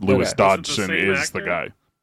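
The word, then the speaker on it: Dodgson: Wong comes back as Dr. Henry Wu, Campbell Scott as Lewis Dogson. Does that last name sound familiar to anybody? – I mean, you said it wrong. Because it's Dodson. Dodson. We've got Dodson here.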